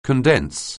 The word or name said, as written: condense